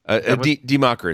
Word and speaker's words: Die Macher: Die Macher is pronounced. Die Macher.